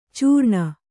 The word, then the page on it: ಚೀರಣೆ: Kannada